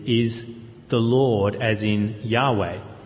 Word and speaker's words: Yahweh: is a translation of the word which we sometimes pronounce Yahweh, a Hebrew word for the name of God, God's name. But we don't really know how to pronounce it,